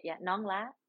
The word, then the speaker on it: Nong La: Nong La is how you say it in Vietnamese.